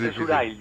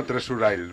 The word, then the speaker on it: Treasure Isle: Es difícil, es Treasure Isle, yo digo. – Isle, ¿no? Treasure Isle.